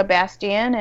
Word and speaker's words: Bastien: You can follow me on Twitter at my first and last name, Angelica Bastien, and Bastien is spelled B-A-S-T-I-E-N.